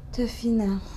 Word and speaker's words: tefina: And we call it tefina. – Is there an English spelling for that? – Tefina. We can, in French, it's spelled T-E-F-I-N-A.